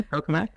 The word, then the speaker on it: tokamak: And most people think of the tokamak design. I hope I'm saying that correctly. – Yeah, tokamak, yeah.